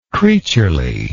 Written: creaturely

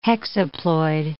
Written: hexaploid

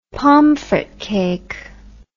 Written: pomfret cake

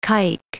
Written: kike

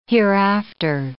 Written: hereafter

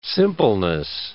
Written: simpleness

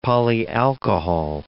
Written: polyalcohol

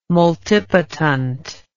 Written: multipotent

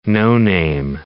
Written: no-name

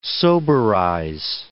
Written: soberize

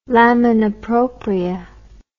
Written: lamina propria

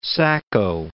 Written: Sacco

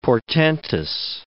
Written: portentous

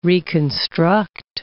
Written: reconstruct

